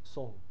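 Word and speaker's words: Seon: and now it's most people know it by the Japanese pronunciation Zen. In Korea it's pronounced Song, right?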